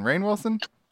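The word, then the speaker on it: Rainn Wilson: Ryan Wilson. Rain Wilson? It's spelled like rain.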